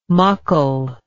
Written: muckle

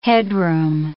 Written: headroom

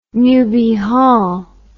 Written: newby hall

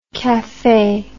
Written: caffe

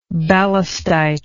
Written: ballistite

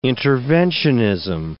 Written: interventionism

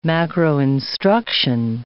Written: macroinstruction

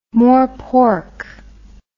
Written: morepork

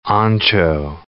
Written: ancho

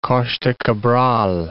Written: Costa Cabral

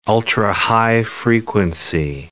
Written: ultrahigh frequency

